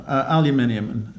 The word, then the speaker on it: aluminium: And the final one, aluminium, just for our North American viewers, that's the way it should be pronounced.